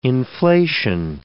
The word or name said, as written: inflation